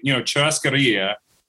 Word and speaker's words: churrascaria: Showbiz kind of meal, Where is churrascaria, am I saying it right? Churrascaria. – So we say churrascaria.